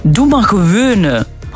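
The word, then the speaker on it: doe maar gewoon: Doe maar gewone. Doe maar, nee, niet gewoon, hè. De G, dat is moeilijk voor de mensen. Doe maar gewone.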